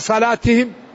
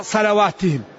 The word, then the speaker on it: صلاتهم: الصلاة تكتب بالواو الصلواة، وتجد العامة يقرأوا الذين هم على صلاتهم يقرأها على صلواتهم، لأن فيه واو يكتب ولا ينطق.